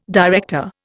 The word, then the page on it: director: Indian